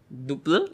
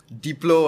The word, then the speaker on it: Duplr: Is that how you pronounce it? Yep. Okay. You, one of the few guys that pronounce it correctly. how, do people pronounce it? Uh, most of it would be like Uh, no, what was the recent guy who said? Uh, Diplo was one.